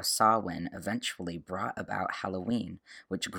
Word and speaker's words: Samhain: And by the way, if you're listening to this, that name Samhain is spelled S-A-M-H-E-I-N. So it's spelled a little differently than you might expect for the pronunciation.